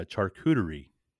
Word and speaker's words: charcuterie: Did you say Chattahoochee boards? – No, charcuterie.